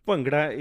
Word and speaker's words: Bhangra: And they did it on Bhangra. Did I pronounce Bhangra properly? – Bhangra is correct pronunciation, and you're very close.